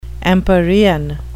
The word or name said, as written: empyrean